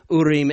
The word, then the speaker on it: Urim: or the I don't even know how other people pronounce it, but Hebrew pronunciation would be Urim and Thummim.